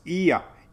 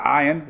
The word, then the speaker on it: Ian: It's Ian. Ian. Not Ion. It's Ian. Ian. Ian. Not Ion. Ian. There's no I in Ion.